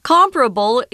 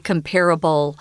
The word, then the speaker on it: comparable: I need, I need to mention quickly, guys, comparable is really the best way to pronounce this word, but you will hear some people say comparable. Um, I know that's probably easier for you out there because compare and comparable are uh, a lot closer.